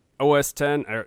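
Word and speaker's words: OS X: I mean, I've heard the thing about OS 10. I said it right.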